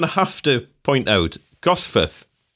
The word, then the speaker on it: Gosforth: But in most people call Gosforth, it's more of a, you could write it as F-U-T-H. That's how it's pronounced at the end, Gosforth.